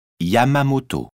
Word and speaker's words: Yamamoto: S-C-H-I-N. – 3. – Je m'appelle Yamamoto. – Comment ça s'écrit ? – Y-A-M-A-M-O-T-O.